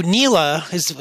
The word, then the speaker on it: NELA: I don't know if it's just NELA or NELA. What was NELA and how big of a role did it play in spreading this false history? – So NELA, that's the way we pronounce it too.